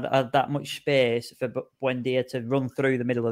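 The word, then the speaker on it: Buendía: Buendia.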